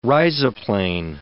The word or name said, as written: rhizoplane